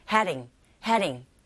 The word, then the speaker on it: heading: Every second heading to 뭐 is jolly 이렇게 발음하면 되는데 이때 heading 발음할 때 heading이 틀린 발음은 아니지만 우리 귀에 들릴 때는요. heading 거의 R 소리 가깝게 들리게 되는데 T 사운드가 강모음과 양모음 사이에서 르처럼 들리는 것과 똑같이 이 D 사운드 역시 모음 사이에서 이렇게 들려요. 르르 heading 이런 식으로 발음하시면 됩니다.